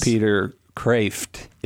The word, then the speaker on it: Peter Kreeft: Peter Kreeft is the proper pronunciation, I'm told.